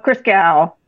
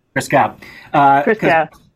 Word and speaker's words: Christgau: Bob Christgau. – Christgau.